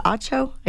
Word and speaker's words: Acho: is it Acho? I always mispronounce.